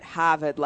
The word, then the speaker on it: Harvard: And not Harvard, like with an R, but Harvard, like hold your shirt up, H-A-H-V-A-H-D. So basically the way Ben Affleck says it. – There you go. – Pack your can. – It's a proper way to say it, all right? – The Boston way to say it.